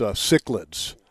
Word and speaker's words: cichlids: Well, again, that's because I just pronounced cichlids and I got corrected. Thank you, Robbie. Robbie said, it's cichlids and they're a predator fish.